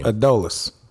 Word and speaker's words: Adolis: And in this game, how about Adolis Garcia, or Adolis, or however you pronounce his name? – Adolis.